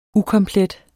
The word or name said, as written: ukomplet